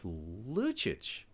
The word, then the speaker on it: Lucic: Is it Lukic? – It's Lucic, but you're close enough. – Lucic.